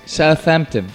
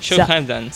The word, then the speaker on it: Southampton: Porque la polémica, teníamos una polémica antes en off, ¿cómo se pronuncia exactamente el nombre del equipo, británico? – Southampton. – Southampton.